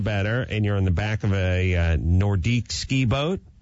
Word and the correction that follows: Nautique: I think that was a Nautique.